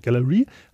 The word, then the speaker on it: Gallery: So Galley hatte es, Gallery, der wird ausgesprochen, Gallery, hatte sich eigentlich als einen Befehl, also alle U-Boot-Jäger sollten U-Boote einfach zerstören.